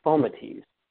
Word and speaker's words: fomites: In the movies or in the cartoons, and people, they say, but it's actually pronounced fomites. F-O-M-I-T-E-S, fomites.